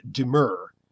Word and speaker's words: demur: Merriam-Webster lists the pronunciation as demur. However, in the Youglish examples, I hear people pronounce demur in various ways, including demure. In other words, they're actually saying the word demure, D-E-M-U-R-E.